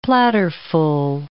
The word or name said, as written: platterful